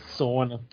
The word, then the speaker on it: sauna: Sauna. It rhymes with Mona. Sauna.